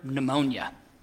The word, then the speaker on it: pneumonia: Furthermore, we don't tell them that sometimes the P is completely silent. You don't even pronounce it, like the word pneumonia.